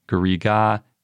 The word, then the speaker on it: garriga: And it's pronounced Garrig in French or Garriga in Occitan.